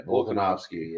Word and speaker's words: Volkanovski: Yeah, Volkanovski.